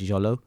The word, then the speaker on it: Xolo: It's spelled X-O-L-O, which I may be mangling the pronunciation, but I think it's Jolo.